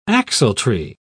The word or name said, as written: axletree